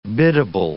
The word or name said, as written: biddable